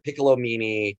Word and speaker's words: Piccolomini: David Piccolomini, but I wanted to make it as easy. DPIC comedy. Or Piccolomini, PICCOLOMEANY, because that's how people were spelling it phonetically.